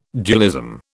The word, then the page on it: dualism: British English